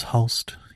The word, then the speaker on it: Hulst: I believe it is Hulst. H-U-L-S-T.